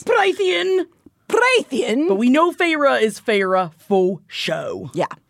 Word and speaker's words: Feyre: As the book opens, we're seeing things from the eyes of a young woman named Feyre, which thankfully Sarah works a clever pronunciation of this name onto page 13 of the paperback because I probably would have been calling her fa- fair, fair the entire time.